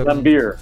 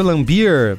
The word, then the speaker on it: Laimbeer: E só pra gente encerrar aqui essas controvérsias, o pivô do Detroit Pistons, né, na época o Bill Laimbeer, é assim a pronúncia? – Laimbeer. – Sabe falar? Bill Laimbeer, boa.